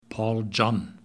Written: paul john

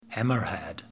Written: Hammer Head

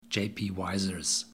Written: J.P. Wiser's

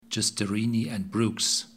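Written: Justerini & Brooks

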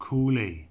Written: Cooley

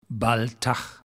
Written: Baltach

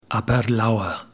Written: aberlour

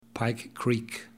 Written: pike creek

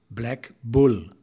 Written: Black Bull

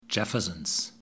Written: Jefferson's

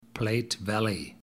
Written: platte valley

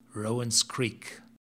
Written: rowans creek

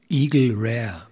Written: Eagle Rare

